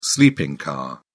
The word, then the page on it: sleeping car: British English